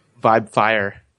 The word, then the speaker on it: Vibefire: And I'm also known as Vibefire. – Oh, okay, nice! – They're dropping the name. Uh, and what was it again? Vibefire.